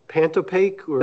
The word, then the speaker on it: Pantopaque: How do we pronounce this? Pantopaque? – That's correct.